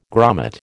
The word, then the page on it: grommet: American English